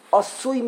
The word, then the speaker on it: Asui: Asui means complete. Now, by the way, you can pronounce it Asui even without the Yud. Even if you don't, the Yud is silent, you can also say Asui.